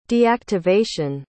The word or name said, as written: deactivation